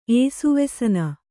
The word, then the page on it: ಏಸುವೆಸನ: kannada